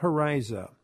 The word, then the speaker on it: Horyza: And Helen's last name is spelled H-O-R-Y-Z-A, Helen Hariza.